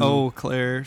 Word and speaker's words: Eau Claire: E-A-U, pronounced Eau. – Eau Claire, French.